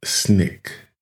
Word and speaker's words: SNCC: SNCC, pronounced SNCC.